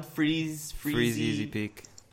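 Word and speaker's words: Freezeezy Peak: Freezy, say it again, what? – Freeze Easy Peak. – What, say it again? Freezy Easy Peak. – Yes. – Did I say it right? Freezy Easy Peak, okay.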